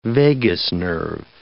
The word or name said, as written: vagus nerve